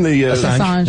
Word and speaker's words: Assange: Assange.